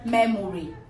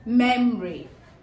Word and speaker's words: memory: So when you need to pronounce it, it's not memory. Mm-mm. When you need to pronounce this word, it's memory. The O there is giving us a schwa sound. It has three syllables and the stress is on the first syllable.